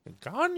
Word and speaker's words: Gagnon: Man, I'm usually on top of Mets spellings and pronunciations, but just think about how Adam Sandler would say his name. That's the clue there. Gagnon, you know. That's the way to pronounce it.